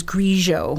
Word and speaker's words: Grigio: You will hear people say, and apparently in France, they do say Pinot Grigio, which is apparently fine, but the Italian pronunciation is Grigio.